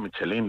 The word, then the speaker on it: Michelin: Yo digo Michelin. Lo que pasa es que hay mucha gente que le pone tilde en la I.